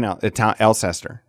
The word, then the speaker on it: Alcester: I grew up in Alcester. That's how you say it, okay? Dean, the other Dean, said it sounds like a skin disease. They got a case of the Alcester.